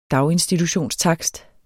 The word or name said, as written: daginstitutionstakst